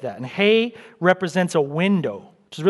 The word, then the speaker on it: He: Not he, but hey.